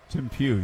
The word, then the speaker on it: Tim Pugh: Is it Tim Pugh, P-U-G-H? – Tim Pugh, yes.